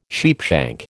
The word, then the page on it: sheepshank: American English